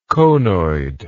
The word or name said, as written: conoidally